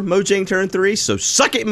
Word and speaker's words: Mojang: Mojang, I'll even pronounce it right this time, for making the company what it is today.